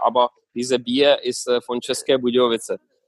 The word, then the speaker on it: České Budějovice: Nämlich bei, jetzt werde ich auch gucken, ob ich das richtig ausspreche, Dinamo České Budějovice oder so. Dinamo České Budějovice und das ist ein Stadt vom, das ist Bierstadt.